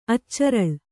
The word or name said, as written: ಅಚ್ಚರಳ್